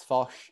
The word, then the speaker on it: Fosh: My name is Max Foch. Just type that into any platform that you like. I'm on basically all of them. Foch is spelled FOSH. It's like fish, but with an O. So Max Foch.